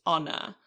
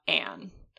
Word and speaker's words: Anne: Anne, like the E in Dutch is pronounced, so it's Anne, not Anne.